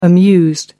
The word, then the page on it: amused: American English